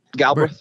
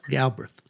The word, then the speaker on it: Galbreath: Do we pronounce his name Galbreath or Braith? – Yeah, Galbreath. Yep. – Galbreath.